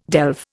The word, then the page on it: delve: Indian